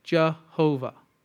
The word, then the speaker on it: Jehovah: And then later, in the Middle Ages, and then going from Latin to German, they say, oh, the way you pronounce this name of God is, according to the vowel points, Yehovah. Yehovah. And then when it comes into German, you take the ya and you make it a ja. Instead of Jesus, you make it Jesus. Ja, Jesus. So how do you say it? Jehovah.